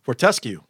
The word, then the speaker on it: Fortescue: Fortescue. – Fortescue. Fortescue, that's the Australian iron ore metal guys. That's F-S-U-G-Y.